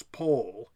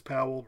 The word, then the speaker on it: Powell: Charles pronounced it rhyming with toll, so it's Charles Paul. Jonathan pronounces Powell rhyming with towel. Two brothers, two different pronunciations.